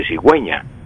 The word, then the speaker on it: cigüeña: Si yo no colocara la diéresis en la U de cigüeña, me quedaría cigueña la cigüeña Entonces, para que quede cigüeña y suene la U, se le colocan los dos puntos a la U y entonces queda cigüeña para que se pueda pronunciar eh, correctamente, cigüeña.